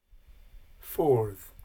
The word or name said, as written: fordh